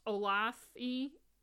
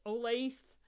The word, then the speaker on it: Olathe: Olathe, Kansas, Olathe, O-L-A-T-H-E, Kansas, the police were ready for him.